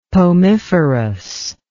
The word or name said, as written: pomiferous